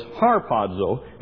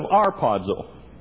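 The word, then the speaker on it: harpazo (ἁρπάζω): Okay, it's an A, and the reason it's pronounced huh, it has a rough breathing mark over it, and so it's harpazo instead of arpazo.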